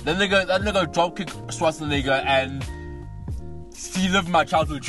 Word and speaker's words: Schwarzenegger: I don't know how to pronounce his surname. – Schwarzenegger. – I should be embarrassed because he's been in our Schwarzenegger. That's how he fucking pronounces his name.